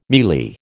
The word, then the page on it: mealie: American English